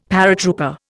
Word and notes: paratrooper: Indian

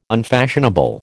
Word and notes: unfashionable: American English

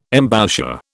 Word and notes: embouchure: British English

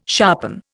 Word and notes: sharpen: Indian

